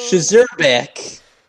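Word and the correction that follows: Szczerbiak: His name is Wally Zerbiak. – Zerbiak, so yeah, the S is silent.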